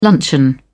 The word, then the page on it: luncheon: British English